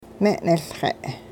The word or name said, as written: me'niLxe'